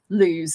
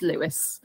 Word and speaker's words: Lewes: you'd be pronouncing it lose. So yeah, but it is Lewis. – So we all know now, everyone listening, it's Lewis.